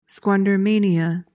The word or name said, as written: squandermania